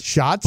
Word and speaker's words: Schatz: Schatz.